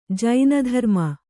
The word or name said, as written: ಜೈನಧರ್ಮ